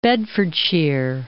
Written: Bedfordshire

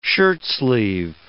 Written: shirtsleeve

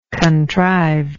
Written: contrivedly

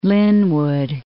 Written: Lynwood

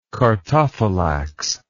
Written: chartophylax

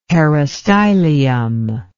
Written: peristylium

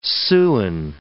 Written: Siouan

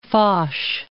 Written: Foch

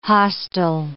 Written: hostel

